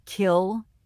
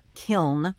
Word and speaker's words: kiln: First off, the use of the word kill for kiln with an N at the end is well known among linguists. This is actually a pronunciation that we have record of dating back into the Middle Ages. Mm. Obviously, now in English, the more common word is kiln with the N on the end.